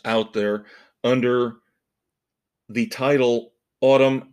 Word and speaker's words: Atum: Autumn, ATUM, but I believe Billy Corgan has already come out and said you pronounce it like the season autumn.